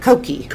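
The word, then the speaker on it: Cocchi: I've read it a million times, but how do you pronounce it? – Cochi. – Cochi. – But, I mean, any American reading that word would say Cochi.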